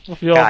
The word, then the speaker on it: Cagliari: No, non lo pronuncia così, lo pronuncia Cagliari. Cagliari. Proprio male.